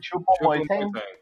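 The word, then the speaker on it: Choupo-Moting: Chupo Moiting.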